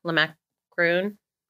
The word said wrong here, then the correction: Le Macaron: Cron. The French bakery. – Le Macaron.